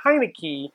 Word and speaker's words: Heinicke: They're now down to their third string, who is now their first string, Taylor Heinicke, which no one can figure out how to say his name, from Hickory, Dickory Dock, to Heineken, to Hennessy, Young Henny. But I know it's Heinicke, and we don't know what they're going to do next week.